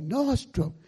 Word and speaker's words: Nordstrom: Is it Nordstrom or storm? Nordstrom. Oh, Nordstrom.